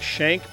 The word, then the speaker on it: Shank: My name is Nick Shank, pronounced like a prison knife, but spelled differently, of course.